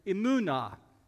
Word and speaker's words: emunah: Another Hebrew word in that family is emunah. Am I saying it right? Yeah, emunah.